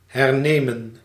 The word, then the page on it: hernemen: /ɦɛrˈneːmə(n)/